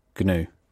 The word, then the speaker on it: GNU: It's a hard G, like GRU, but with a letter N. GNU. – GNU. Oh, okay. – Yeah. And do you know what it stands for? – GNU's not...